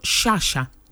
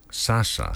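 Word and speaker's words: Sciascia: Λέγεται Λεωνάρντο Σάσα. – Σάσα. – Σάσα, έτσι όμως, με παχύς. – Είναι το έστω τελικό με το σι που διαβάζεται παχύ. – Ναι, ναι, ναι. Σάσα. Δεν είναι σιάκα ή αυτό.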